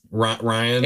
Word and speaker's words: Rian: Ryan? – It's spelled stupidly, but it's pronounced normally.